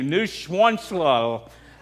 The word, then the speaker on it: Neuschwanstein: Neuschwanstein.